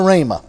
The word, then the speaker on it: Ramah: So Samuel rose up and went to Ramah.